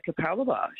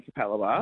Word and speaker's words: Capalaba: And it took me ages to learn how to say Capalaba. – Oh, well, it might not be my show you were enjoying then. – Because that's how, if you look at it, it's C-A-P-A-L-B-A, Cap-L-Laba.